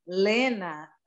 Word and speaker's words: Lena: Elisa, actually, in my language, they actually call me Lena. It's spelled different, but it's almost the same as your wife. Hey. It's spelled L-E-N-A. American says Lena.